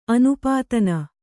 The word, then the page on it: ಅನುಪಾದಾನ: Kannada